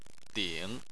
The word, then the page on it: ding: 3